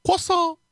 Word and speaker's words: croissant: It's croissant. It's pronounced croissant.